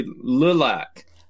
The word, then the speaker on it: Lilac: No, it's actually Lilac. – Lilac. Okay. – Yeah, like little AKK, Lilac.